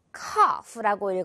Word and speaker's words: cough: 자, 마지막은 자, 이 단어는 여러분 조금 어려울 수도 있을 것 같은데 cough라고 읽어요. 일단 이 부분 OU를 코우후가 아니라 발음 길게 끌어주면 돼요.